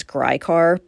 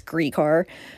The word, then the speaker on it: Gricar: Ray Gricar, you know, I've been pronouncing his name wrong for years. I always thought it was Gricar, but it's Gricar.